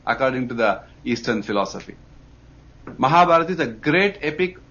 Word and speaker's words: Mahabharata: I will pronounce it for you. – Okay. – Mahabharata. – Mahabharata.